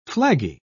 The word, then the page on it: flaggy: British English